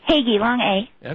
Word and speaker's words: Hagee: Hagee, long A.